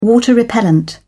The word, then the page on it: water-repellent: British English